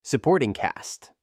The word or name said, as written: suporting cast